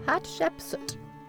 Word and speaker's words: Hatshepsut: Hatshepsut.